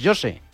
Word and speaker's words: José: Pero José, en portugués, José.